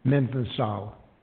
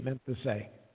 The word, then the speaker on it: mainesthai: So M-A-I-N-E-S-T-H-A-I is pronounced how? – Menthesal. Menthesae.